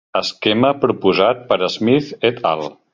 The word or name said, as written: proposat